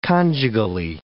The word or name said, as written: conjugally